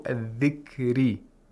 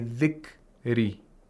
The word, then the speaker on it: الذِّكْرِ: And also الذِّكْرِ. الذِّكْرِ. Again, we have ikhtilas of ra, where we have to quickly pronounce the ra. We shouldn't say min ba'di dhikri, dhikri, dhikri, dhikri.